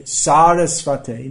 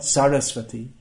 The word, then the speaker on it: Sarasvate: Namaste, Sarasvate, not Sarasvati. If you say Sarasvati Devi, you are converting Srila Prabhupada into a goddess.